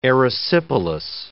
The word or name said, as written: erysipelas